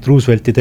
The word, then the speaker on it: Roosevelt: President Roosevelti teemadel, ta vaatas Roosevelti hääldusele peale väga juhmi näoga otsa ja siis küsis, et do you mean Roosevelt? – Roosevelt.